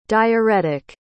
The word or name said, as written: diarrhetic